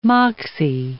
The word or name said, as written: moxie